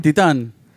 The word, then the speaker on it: Titane: Yes, Titane. – The what? – Is it? – We. – Huh? Oh, you learned. – Is that the correct pronunciation? – Titane. – Oui.